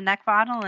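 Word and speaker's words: Neckvatal: LinkedIn is Brenda Neckbottle, and you know the last name is spelled N-E-C-K, like the thing you want to choke. V as in Victor, A-T-A-L.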